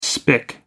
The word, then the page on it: spick: American English